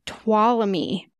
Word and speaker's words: Tuolumne: But apparently it's Tuolumne. You don't even say the N. That, like, if you look at the word, you would not guess Tuolumne.